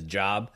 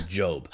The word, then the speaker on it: Job: But the seminarian inside my brain, because I used to want to be a preacher, will not let that name pronunciation as Job go by. That is Job.